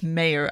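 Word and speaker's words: Meijer: And from reporting that has come out, Representative Mayer, it's not Meyer, it's Mayer.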